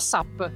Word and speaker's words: SUP: Grazie, allora ci sono anche altri due ragazzi che sono arrivati anche loro in SUP. SUP o SAP? – Allora, c'è chi dice SAP, comunque deriva dall'acronimo Stand Up Paddle americano, quindi c'è a seconda della pronuncia, se lo vuoi pronunciare all'italiana.